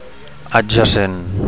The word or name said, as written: adjacent